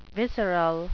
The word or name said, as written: visceral